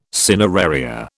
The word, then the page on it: cineraria: British English